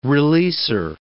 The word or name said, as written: releaser